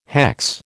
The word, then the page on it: hex: American English